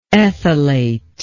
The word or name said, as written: ethylation